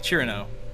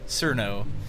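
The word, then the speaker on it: Cirno: It's kind of like how you detect the Touhou newbies, because you realize once you get into like the Japanese pronunciation, it's pronounced like Cherno. Because they, apparently cannot, or they are incapable of saying Cerno. And then another interesting factoid: the Finnish say Sirku. I don't know why they say it like that, but that's just how Finns are.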